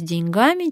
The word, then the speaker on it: деньгами: Сегодня разберемся, с деньгами или с деньгами. Хотелось бы, конечно, сделать это масштабно, и чтобы навсегда найти ответ на вопрос, где деньги. Но в нашей власти только ударение. Одно ни у кого не вызывает сомнения. Лучше с деньгами, чем без них. Правильный ответ – деньгами.